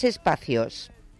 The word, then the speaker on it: Espacios: Pues mira, lo primero, muy interesado en saber si lo he dicho bien, si es Espacios o es Espacios. – Se pronuncia Espacios, ya, como si fuese la palabra escrita normal, pero realmente está semi en latín.